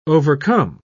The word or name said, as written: overcome